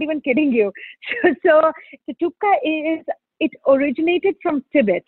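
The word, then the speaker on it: thukpa: Can you talk to us about your, I don't know if I'm pronouncing it correctly, tupka, chicken noodle soup? – Tupka.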